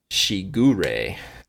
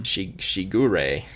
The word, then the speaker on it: Shigure: So TM23 is Shigure. – Awesome. – Shigure. – So that is... – It says hashtag Japanese pronunciation bot.